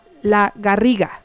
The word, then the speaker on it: La Garriga: Ah, bueno, ya nos escribió Johnny, dice, la ciudad donde él está se llama La Garriga. Así se pronuncia. A ver, corrígeme, La Garriga. Bueno, saludos hasta La Garriga, desde Chiapas, para Barcelona, La Garriga. Espero que se pronuncie así, si no, corrígeme, Johnny. Y muchas gracias por estarnos sintonizando. Un fuerte abrazo hasta tu casa, tu ciudad. Dice que sí lo dije bien.